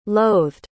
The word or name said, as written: loathed